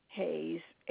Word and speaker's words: Hayes: Hayes, H-A-Y-E-S.